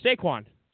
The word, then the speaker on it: Saquon: Sure, Saquon.